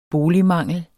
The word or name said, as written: boligmangel